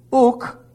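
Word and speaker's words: u (οὐκ): De ote, e, and then we have the word, uk is the first word. Uk is the negative. There are two negatives used in the original language. Now it's simply pronounced u, but it's a negative.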